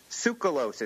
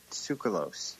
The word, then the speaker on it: Tsoukalos: How do you say his last name? – Tsoukalos. As far as I know, I'm pretty sure it's Tsoukalos.